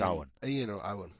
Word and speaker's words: Eoin: Ian Morgan, how do you reckon you'd pronounce that? E-O-I-N. – I won't be correcting you again today. – Well, you don't. Owen Morgan, Ian. – It's Owen.